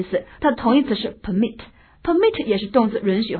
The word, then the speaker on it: permit: 所以 permit 动词读作 permit，名词是 permit。